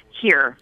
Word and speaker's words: hir: So is it, do you say that just H-I-R? – It's actually pronounced here.